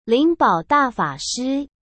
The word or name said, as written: Lingbao Dafashi